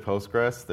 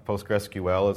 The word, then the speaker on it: PostgreSQL: I will pronounce it Postgres. There's a lot of PostgreSQL is the official pronunciation, but that's probably the only time you'll hear me say that. I really prefer Postgres, which is the unofficial name.